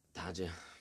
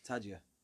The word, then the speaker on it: Taggia: Yeah, and like we said, we're 2 days into a 4-week stint in Armadatija. – Taggia. – Taggia. Sorry, my pronunciation isn't great.